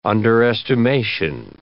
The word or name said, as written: underestimation